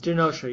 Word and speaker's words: Genosha: There was all this stuff about Genosha. I'd forgotten about Genosha. Is that how it's pronounced? – Genosha, yeah.